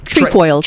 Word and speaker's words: Trefoils: Trifoils? – Trefoils.